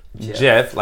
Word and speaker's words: GIF: GIF, like the peanut butter. – And not GIF.